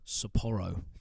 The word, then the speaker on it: Sapporo: Sapporo.